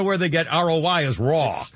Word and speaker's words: Roy: And Patrick Raul, we pronounce Raul, it's spelled Roy, R-O-Y. Patrick Raul, how do you pronounce Raul? Raul? So I'd rather pronounce that. Patrick Raul, the Hockey Hall of Famer, 61. – Raw. Patrick Raw.